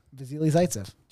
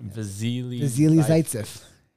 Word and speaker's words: Vasily Zaitsev: Vasily Zaitsev. – Job. – Thanks, man. It's a hard name. – It is a hard name. I couldn't pronounce it. – Vasily Zaitsev.